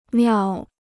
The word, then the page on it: 庙: miào